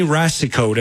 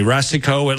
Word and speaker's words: Racicot: But the bottom line is there's a 41-year-old woman in Maine named Jenny Racicot. And I hope I'm pronouncing her name correctly. Racicot, it looks like, and it may be a French name, R-A-C-I-C-O-T.